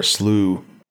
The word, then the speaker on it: slough: You know, I found out recently that slough and slough are two different words, even though they're spelled the same. – What? What's slough? – It's like a swamp. It's spelled the same as slough, but it means swamp, and it's pronounced slough or slough.